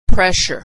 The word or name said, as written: pressure